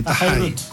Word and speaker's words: Tahai: Convengamos que se escribe T-A-H-A-I, porque muchos preguntaban cómo se escribía para buscar. – Sí, que en la isla la H se pronuncia como una J más suave. Tajai.